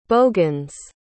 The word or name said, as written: bogans